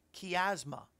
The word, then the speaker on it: chiasma: Well, Rabbi Jonathan Cahn said that it's called the, and I hope I'm saying it right, the chiasma, or chiasma, C-H-I-A-S-M-A, chiasma, but I'm sure the C-H is more of a k sound.